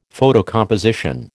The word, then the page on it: photocomposition: American English